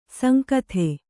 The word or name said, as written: ಸಾಂಗತೆ